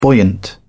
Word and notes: buoyant: British English

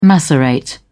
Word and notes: macerate: British English

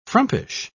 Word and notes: frumpish: British English

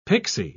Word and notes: pixy: British English

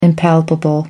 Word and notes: impalpable: American English

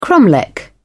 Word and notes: cromlech: British English

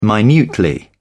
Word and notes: minutely: British English

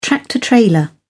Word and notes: tractor trailer: British English

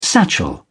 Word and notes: satchel: British English

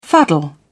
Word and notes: fuddle: British English

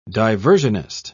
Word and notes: diversionist: British English

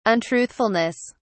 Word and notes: untruthfulness: British English

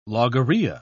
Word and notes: logorrhea: British English

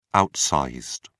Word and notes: outsized: British English

